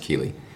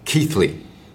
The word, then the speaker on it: Keighley: Now, it turns out Keighley is not pronounced Keighley, but Keithley.